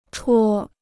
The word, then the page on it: 戳: chuō